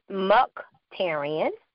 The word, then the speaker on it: Mukhtarian: My name is Dr. Kimberly Mukhtarian. And it's actually spelled how it's pronounced. It's pronounced how it's spelled.